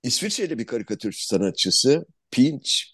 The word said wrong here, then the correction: Pitch: Pitch Comment e, P I T C H diye okunuyor yanlış anlaşılmasın.